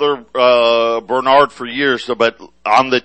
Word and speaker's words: Brainard: When Lael Brainard gets put into place, Jack and I came up with this piece because you say Brainard, that's kind of how you pronounce it, but you look at all you see is brain.